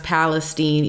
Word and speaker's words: Palestine: They say it's pronounced Palestine, East Palestine, Ohio.